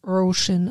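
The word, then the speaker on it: Róisín: Ist ein irischer Frauenname. Und wird Roisin ausgesprochen. Roisin, siehst du.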